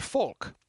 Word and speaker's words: folk: like the next word, folk. F-O-L-K. Pronounce the L. Folk.